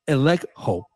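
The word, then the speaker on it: elekho (ἐλέγχω): Now, the word expose in Ephesians 5, 11 is the Greek verb elekho. Elekho is spelled E-L-E-G-C-H-O. E-L-E-G-C-H-O,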